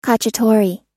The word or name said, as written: cacciatore